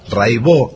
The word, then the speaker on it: Raibaut: Se escribe Raibaut, métanse, búsquenla, Rai con Y, Baut con B larga, Catalina Raibaut, Raibó se dice, y bueno, Cotidiano son 10 canciones, hay un documentalito muy lindo.